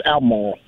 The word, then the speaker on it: Albemarle: Well, I didn't call it Albemarle. I said Albemarle. – Yeah, but you got the B in there. You got to drop the B. It's Albemarle.